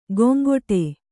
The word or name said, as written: ಗೊಂಗೊಟೆ